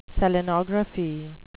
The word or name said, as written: selenography